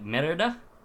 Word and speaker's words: Merida: It's actually said with an a sound, a long a at the end. Like Merida.